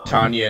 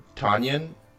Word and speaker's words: Tanyan: Tanya and Kari, yeah. – Which is not how you actually pronounce those words. The word Tanyan is Arabic for dragon.